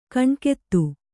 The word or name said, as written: ಕಣ್ಗೆತ್ತು